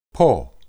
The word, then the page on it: poh: Hebrew